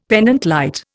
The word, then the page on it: pendente lite: Indian